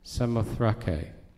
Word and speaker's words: Samothrake: I used to pronounce it Samothrace, but then I looked it up, and it's Samothrake.